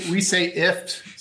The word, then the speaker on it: IFTTT: Well, we say IFT. So kind of like a gift without the G.